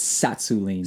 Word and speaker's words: Tsatsouline: That's Pavel Tatsulin. – Uh, I believe it's Tsatsouline.